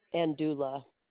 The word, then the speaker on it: Andula: Andula. – You want to spell that? – A-N-D-U-L-A. – Oh, so you spell it just like you pronounce it.